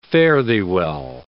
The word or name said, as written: fare-thee-well